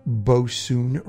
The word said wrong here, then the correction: bosun: wrong on the last show, and we got a really nice email from Rose, a really nice voicemail from Rose, who corrected my pronunciation, and she said it's actually bosun, and it's equivalent to a petty officer in the modern Navy.